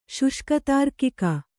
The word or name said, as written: ಶುಷ್ಕತಾರ್ಕಿಕ